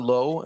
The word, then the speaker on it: Loew: Jon Loew, am I pronouncing your last name correctly, Jon? – You are.